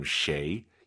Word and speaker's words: 谁: 쉐이, 쉐이 해도 알아듣지만 정확한 발음 쉐이, 입을 가로로 쫙 찢으면서 쉐이 발음하시기 바랍니다.